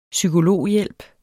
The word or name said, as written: psykologhjælp